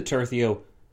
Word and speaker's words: tercio: My pronunciation of this word has gone from tercio to tercio to tercio to tertio, so it's going all over the place. But now we've landed on tercio, which I'm told is correct.